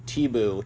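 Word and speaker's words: Tibau: I also want to mention that I just noticed that Gleison Tebow, that's how it's pronounced, is fighting on the prelims on Fight Pass in the first fight.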